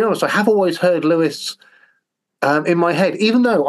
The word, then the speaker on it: Lewis: So I have actually checked in with an American and said, how would you say this name? And they said, no, that's Lewis. It wouldn't be Louis as in King Louis of France or something like that.